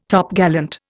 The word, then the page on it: Topgallant: Indian